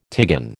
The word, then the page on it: tigon: American English